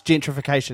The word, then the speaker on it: gentrification: And so he had to say the line and he goes, I think he said it wrong at first. – Yeah. – He goes, gentrification. And then I was like, oh, it's gentrification. And he was like, oh, yeah, gentrification.